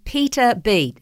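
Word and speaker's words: Peta Bee: Now, it was written by Peter B. Her name is spelled P-E-T-A, so it's a lady, Peter, and her last name is spelled like B, as in a buzzing bee, you know, B-double-E.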